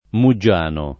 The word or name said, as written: Muggiano